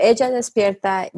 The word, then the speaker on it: Ella despierta: My tag is Ella despierta, which is in Spanish, and I'll also I'll spell it out for you guys. But Ella despierta means she awakens.